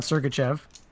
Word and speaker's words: Sergachev: Sergachev, is that how you pronounce it? – That's how they say it here. – Okay, so they sent down Sergachev.